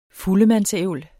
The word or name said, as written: fuldemandsævl